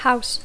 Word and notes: house: English

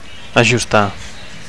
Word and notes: ajustar: Catalan

